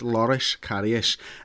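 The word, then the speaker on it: Loris Karius: Lloris Karius, I keep pronouncing this name wrong, it's Lloris Karius, feels let down by Jurgen Klopp because we've signed a boss goalkeeper.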